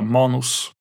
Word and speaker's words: Manos: Efternamnet stavas M-A-N-O-S, kanske uttalas Manus på engelska, men jag kommer säga Manus.